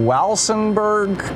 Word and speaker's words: Walsenburg: Meredith in Walsenburg, Colorado. Am I saying that right, Meredith? – Yes, that's close enough. – Okay.